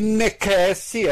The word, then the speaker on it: necesse: teria dito: navigare necessi est. Necesse se escreve com C, mas se lê K, muito provavelmente.